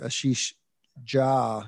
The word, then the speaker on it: Ashish Jha: So there was a thread this past week by Dr. Ashish Jha, I may be pronouncing his last name.